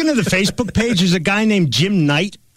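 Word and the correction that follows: Knecht: who, is that how you pronounce his last name, Killer? It's Jim... – Neck? – Jim Neck. – Maybe it's K-N-E-C-H-T.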